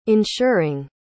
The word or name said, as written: ensuring